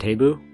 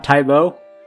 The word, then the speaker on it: Tabu: Is it spelled taboo, but it pronounced it Tabu? – It's like T-A-B-U. – It's a massive brothel. – That's a different part of town. – It's not Tybo.